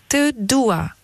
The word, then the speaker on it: Te dua: Et alors, euh, est-ce que vous pouvez nous, apprendre à bien le prononcer Comment on le dit Alors, ce je t'aime en albanais, dites-nous. Te dua Te dua. C'est bien prononcé Oui, oui, c'est bien prononcé.